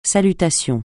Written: salutations

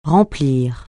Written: remplir

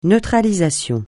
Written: neutralisation

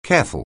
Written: careful

